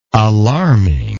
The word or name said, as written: alarming